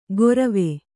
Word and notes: ಗೊರವೆ: Kannada

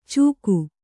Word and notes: ಚೂಕು: Kannada

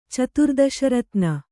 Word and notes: ಚತುರ್ದಶರತ್ನ: caturdaśa ratna

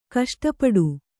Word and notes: ಕಷ್ಟಪಡು: kaṣṭapaḍu

